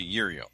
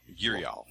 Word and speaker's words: Yrial: I've always called it Yriel. Yriel. – Y-R-I-A-L. – Yriel. Like Ariel, but with a Y.